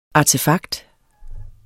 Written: artefakt

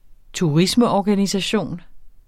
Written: turismeorganisation

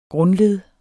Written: grundled

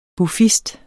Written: buffist

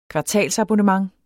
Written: kvartalsabonnement

